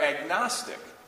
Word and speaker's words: agnostic: They're agnostic. G-N-O-S-T-I-C, gnostic, silent G, means knowledge.